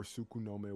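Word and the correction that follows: Tsukuyomi: I thought it was like Tsukuyome. – You know what? I've never said that word like out loud. – Yeah, Y. It's a Y instead of an N, but in the dub they kind of pronounce it the way Mel just said.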